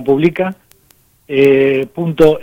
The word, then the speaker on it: Publica Now: tal cual como suena, Publica, .now, o sea, Publica Now, sería pronunciado en inglés.